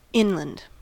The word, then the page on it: inland: American English